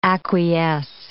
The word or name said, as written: acquiesce